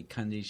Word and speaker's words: Kunde: And I actually, one time I was working with a lady on the East Coast, and all day long she was, introduced me as Jeff Kunde, and I finally said, it's really Kundi.